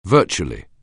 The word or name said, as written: virtually